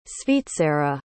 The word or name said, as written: svizzera